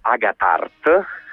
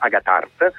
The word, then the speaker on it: Agathart: che si chiama appunto Agathart, con l'accento sulla seconda A, quindi sulla terza, sulla terza, Agathart.